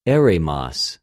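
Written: ἔρημος

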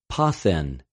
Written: ποθεν

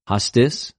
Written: ὁστις

